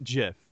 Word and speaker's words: GIF: The creator pronounces it Jif.